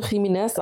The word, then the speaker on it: Jimenez: Also ich muss jetzt dazu sagen, ich habe erstmal von Abby Jimenez, spricht man sie so aus? – Ich vermute Jimenez, aber richtig.